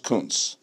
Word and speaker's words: Kuntz: It's pronounced Kuntz.